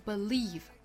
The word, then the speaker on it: believe: 这个词如果不是名词的话，如果是动词相信的话，读成 believe。它有一个特殊的点哈，就是它可能有两种发音方法，一个就是 believe，前面这个音节发成刷 sound，还有一种呢就是发成 a。